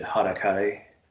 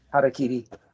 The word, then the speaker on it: harakiri: You commit harakiri. – How to say that right? – Harakiri.